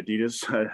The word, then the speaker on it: Adidas: In equities, one of them you mentioned this morning on the internal meeting, was Adidas, Americans say Adidas.